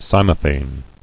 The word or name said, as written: Cymophane